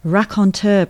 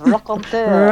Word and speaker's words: raconteur: Can you tell me about um, Raconteur Productions? Uh, yes. – Have I pronounced it right? – You have. Raconteur.